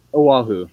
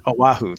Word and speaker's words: Oahu: And lastly, I'm probably going to mispronounce it, so I won't even try, but that island you mentioned is magnificent. – Oahu, yeah. – Oahu, there we go. I wouldn't have said it correctly.